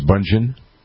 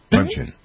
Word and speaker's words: Bündchen: The son of Tom Brady and Gisele Bündchen, that's how we pronounce her name, right? It's Bündchen? Bündchen. Bündchen. Okay.